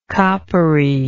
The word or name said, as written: coppery